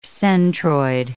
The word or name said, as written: centroid